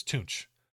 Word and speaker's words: Tunch: It's Tunch, native tongue. Most people didn't say it right, and we had to learn.